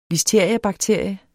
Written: listeriabakterie

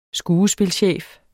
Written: skuespilchef

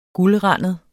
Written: guldrandet

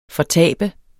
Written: fortabe

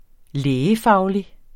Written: lægefaglig

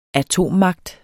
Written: atommagt

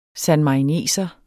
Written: sanmarineser

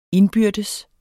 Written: indbyrdes

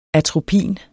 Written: atropin

